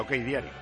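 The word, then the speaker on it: OKDiario: Y creo que OC Diario se pronuncia OK Diario.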